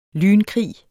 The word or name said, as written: lynkrig